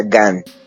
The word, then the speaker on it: Gahan: De hecho, de hecho es, em, pues escribí, ayer, ayer estaba leyendo y el, vocalista David Gahan, pero realmente se pronuncia Gan.